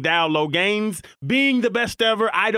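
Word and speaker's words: Dowell Loggains: What is the pronunciation, Falero? – Dowell Loggins.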